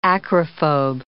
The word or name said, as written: acrophobe